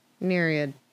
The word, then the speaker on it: Nereid: I need to Google how to say that word. I. Mm-hmm, how to pronounce. Nereid. Is it just Nereid? Nereid. Any of the sea nymphs held in Greek mythology to be the daughters of the sea god Nereus. So it's a Nereid.